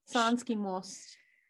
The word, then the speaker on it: Sanski Most: Sanski Most.